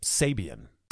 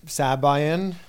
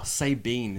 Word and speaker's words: Sabian: Sabine is my favorite one. Sabine. B E A N. Say bean.